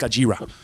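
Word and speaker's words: Gojira: That's how they used to say it. It's like Gajira. It was actually pronounced Gajira.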